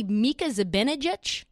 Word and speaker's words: Mika Zibanejad: Actually, Mika Zibanejad, is that how you say his name? – Yeah, yeah.